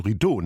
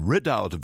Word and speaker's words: Ridout: Hab ihn längere Zeit falsch ausgesprochen. Ich dachte, man spreche das Französisch. Rideau, nein, Ridout, very British.